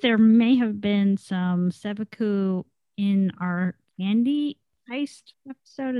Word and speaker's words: Seppuku: but I'm gonna try to say this word. Seppuku. – That's a good pronunciation, I think.